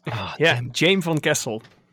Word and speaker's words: Jaime Van Kessel: Jaime Van Kessel.